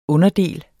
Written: underdel

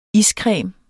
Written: iscreme

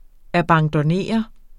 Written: abandonnere

